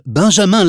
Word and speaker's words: Benjamin: L-A-C-A-R-R-A-U. – 2. Bonjour, je m'appelle Benjamin Lafitte. Mon prénom est B-E-N-J-A-M-I-N. Et mon nom est L-A-F-I-T-T-E.